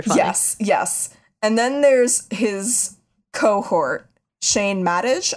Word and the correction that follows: Madej: I think his last name is pronounced. – I don't know. Oh, no, it's Madej. – Madej.